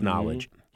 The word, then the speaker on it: Knxwledge: Now, Knowledge, and it's spelled K-N-X-W-L-E-D-G-E, but it's pronounced Knowledge.